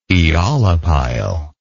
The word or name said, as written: eolipile